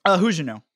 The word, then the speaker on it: Huguenot: Is it Huguenot or Huguenot? I don't know. – Huguenot. – Oh, okay. Good. I've been saying it right this whole time.